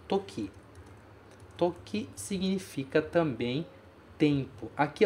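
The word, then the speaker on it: toki (時): Tempo, toki.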